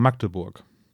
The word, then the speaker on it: Magdeburg: Magdeburg. Ah, jetzt hast du schnell ausgesprochen. – Ich, äh, äh, darf man nicht Magdeburg sagen? Darf nee, das ist mit schnell, äh, wird schnell gesprochen. Magdeburg.